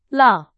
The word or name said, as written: 了